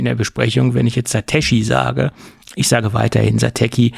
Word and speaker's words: Satechi: Und man kann es wohl auch Satechi aussprechen oder halt Satechi. Ich glaube, die beide Aussprachen ist dem Unternehmen vollkommen nicht egal, aber sie arrangieren sich mit beiden Aussagen. Und ich sage dann weiterhin Satechi, weil ich das in der Vergangenheit schon immer gesagt habe.